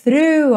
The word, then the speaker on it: through: T-H-R-O-U-G-H. O G-H do final não é pronunciado. Então, pense nela through. Through, ok? Th, linguinha lá na pontinha do dente, through, e você já volta, então, puxando R, through, through.